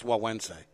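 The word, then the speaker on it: Güegüense: Let me say that before I don't say it in front of his face, because it's G-U-E. – I'm going to tell him right at the show. – Yeah. – How is a cigar that's spelled G-U-E-G-U-E-N-S-E pronounced Wawense? – Yeah.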